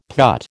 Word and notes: putt: American English

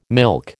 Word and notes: milk: American English

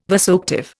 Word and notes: vasoactive: Indian

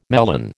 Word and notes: melon: American English

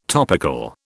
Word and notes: topical: British English

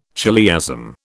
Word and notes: chiliasm: British English

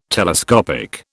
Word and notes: telescopic: British English

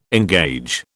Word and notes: engage: British English

